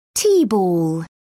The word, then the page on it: tea ball: British English